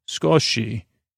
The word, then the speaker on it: sukoshi: What about skosh, a small amount, comes from the Japanese word sukoshi, S-U-K-O-S-H-I, sukoshi. S-U-K-O-S-H-I, sko, which is pronounced, if I would have read a little bit, skoshi, which means a tiny bit.